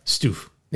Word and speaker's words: Stuf: But the weird thing is, the stuff is just S-T-U-F. – Right. – Stoof.